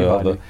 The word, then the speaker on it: Diwali: uh, after Diwali. – Ah, oui. – Yeah, is that how you pronounce it? Diwali? – Yeah, where Diwali, the, Diwali.